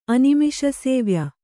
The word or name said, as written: ಅನಿಮಿಷಸೇವ್ಯ